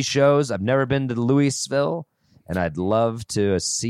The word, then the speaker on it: Louisville: Is that how you say it? Louisville. – That's how you should say it.